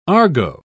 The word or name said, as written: argot